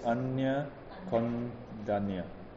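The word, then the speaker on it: Aññā Koṇḍañña: So whenever there's an N with a curly diatrix on top, then you pronounce as Nya. Yeah, like N-Y-A. You pronounce it as N-Y-A. So it's Anya Kondanya.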